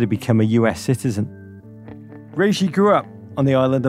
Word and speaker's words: Regis: He actually pronounces it Regis. And you're going to hear a lot of people butcher his surname on this podcast, but it's Regis, as he's become best remembered in US soccer history.